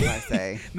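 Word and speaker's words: Marsai: I pronounced her name all types of wrong. What's her name? Marcel. That's an L? That's not an I? – Marseille, I'm sorry. See, Marseille.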